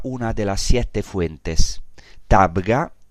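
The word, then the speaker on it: Tabgha: Tabga, es el nombre mal pronunciado del término griego heptapegon quiere decir siete fuentes.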